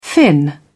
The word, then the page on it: fin: British English